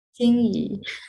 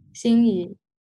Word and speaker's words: Xinyi: Xinyi. Xinyi.